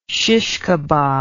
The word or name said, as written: shish kebab